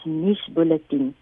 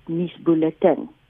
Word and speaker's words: nuusbulletin: Die sesde uitgawe van die Hart dui die uitspraak aan as nuusbulletin. So dan nou een van die opsies uh, eh, wat vir ons gegee is deur Willie. Ehm um, en dis redelik naby aan die 1945 uitspraak. Maar uitspraak word natuurlik ook deur eksterne faktore soos taalkontak beïnvloed. En vanweë Afrikaanse noue kontak met Engels elke dag hoor 'n mens dan al hoe meer ook die uitspraak nuusbulletin. Om dan nou saam te vat, ons is nie regtig so verskriklik as dit kom by uitspraak nie, want uitspraak is veranderlik.